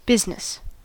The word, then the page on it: business: American English